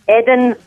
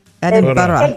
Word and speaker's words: Edinburgh: Edinburgh. – Edinburgh. – Exactly. Exactly. – Edinburgh.